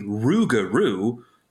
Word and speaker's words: Rougarou: R-O-U-G-A-R-O-U. Rougarou. And that's how I've heard it pronounced in Louisiana.